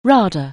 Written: RADA

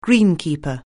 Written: greenkeeper

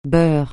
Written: Beur

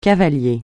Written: cavalier

